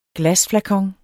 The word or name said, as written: glasflakon